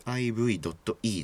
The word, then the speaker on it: Liv.e: live っていうのは、えー、 liv.e ですね。これで live って読むんですけど、はい、このアーティストはめちゃくちゃ僕好きで、えー、 Spotify がなんかで突然知ったんですよね。